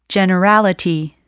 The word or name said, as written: generality